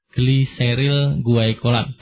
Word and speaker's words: gliseril guaikolat: Gliseril guaikolat. Tulislah sependengarnya apa yang didengar, nanti bisa menyesuaikan. Tulisan sih biasanya bisa dikira-kira. Terbacanya gitu ya, gliseril guaikolat. Meskipun tulisannya guaikolatnya agak unik, tapi bacanya gitulah.